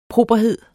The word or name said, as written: properhed